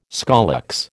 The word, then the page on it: scolex: American English